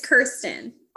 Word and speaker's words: Kirsten: It's Kirsten.